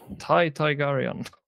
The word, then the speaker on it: Tai Targaryen: Uttalet, hur, hur uttalas det? Tai Targaryen. Tai Targaryen.